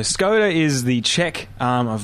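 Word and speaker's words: Škoda: Another interesting car we're looking at, it was at the moment it's only a sketch, but Skoda have revealed a drawing of their Vision C. – Skoda.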